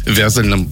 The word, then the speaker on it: вязальном: Прямо сейчас, вот прямо в этот момент моя доченька, выпускница Московского текстильного вуза, работает на смене на московском вязальном производстве. Вязальное — это у них правильно вот такое ударение, да?